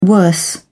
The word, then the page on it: worse: British English